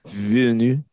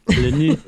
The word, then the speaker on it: Villeneuve: Villeneuve. – Yes. Thank you. – Villeneuve.